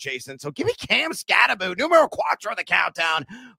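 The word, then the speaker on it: Skattebo: It is not Scattaboo, folks. We got to pronounce it correctly. It is Scattaboo.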